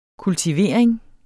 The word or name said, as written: kultivering